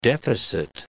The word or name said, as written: deficit